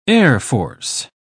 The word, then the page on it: airforce: British English